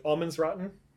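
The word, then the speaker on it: allemansrätten: Yeah, it's like, I don't know, again, pronunciation, but Allmansrätten. – That sounds right.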